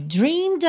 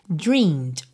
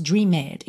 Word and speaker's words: dreamed: Se você optar pelo passado regular, dreamed, o E não é pronunciado, tá? Ele aparece aí, mas você não diz dreamed, e sim dreamed, dreamed.